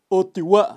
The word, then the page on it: utiwa: Kaqchikel